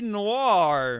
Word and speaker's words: Noire: Does it, did I actually say it right? yeah, that noir. – Noir. Noir. Okay, so uh, looks like uh, you, M, well, why don't you tell us about your hashtag gains in this game?